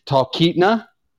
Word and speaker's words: Talkeetna: I'm going to totally butcher this. Talkeetna. – Yeah, you got it right. – You actually hit that one.